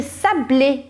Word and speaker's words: sablés: E aí, a gente tem aqui les sablés, sablés.